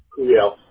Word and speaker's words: Curiel: Curiel. C-U-R-I-E-L.